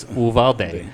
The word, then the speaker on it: Uvalde: That's when I when I read that, that's how it's pronounced. is it's Uvalde. Yeah.